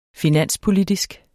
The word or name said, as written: finanspolitisk